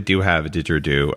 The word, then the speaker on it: didgeridoo: Now, if someone's playing a didgeridoo, I can't even pronounce it, so help me out on that. – Didgeridoo. – Didgeridoo.